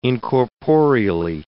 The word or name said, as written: incorporeally